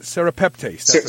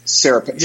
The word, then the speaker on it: serrapeptase: I also have some sereptase. Am I saying that right? Serrapeptase. – Serrapeptase.